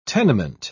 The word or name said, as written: tenement